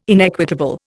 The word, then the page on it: inequitable: Indian